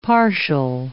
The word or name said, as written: partial